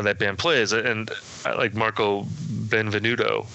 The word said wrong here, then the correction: Benevento: I think I said his name right. The keyboard player. – Benevento, thank you.